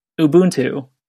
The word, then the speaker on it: Ubuntu: So I looked it up, and it's Ubuntu.